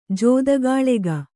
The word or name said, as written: ಜೂದುಗಾಳೆಗ